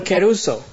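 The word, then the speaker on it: keruso (κηρύσσω): It's a word that is called keruso. – Questa è una parola che in greco si pronuncia keruso.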